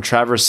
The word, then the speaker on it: Traverse City: I'm from Traverse City, pronounced Travers, Michigan.